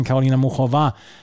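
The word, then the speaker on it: Karolina Muchová: Karolina Muchova heißt sie wohl.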